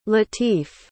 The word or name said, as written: latif